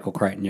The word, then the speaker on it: Crichton: Crichton, yes.